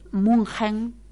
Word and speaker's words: München: Pero realmente nos quedamos impresionados de lo hermoso que era Múnich, Munchen. Como lo pronuncian ellos, o München, algunos dicen, y Múnich en inglés.